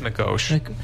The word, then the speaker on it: McGeoch: I don't know how to pronounce it, actually. It's McGeoch. – I think it's McGoach.